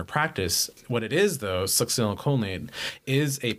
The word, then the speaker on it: Succinylcholine: Succinylcholine. Yeah. – Can you spell it for me? – It's Succinylcholine. O-L-I-N-E.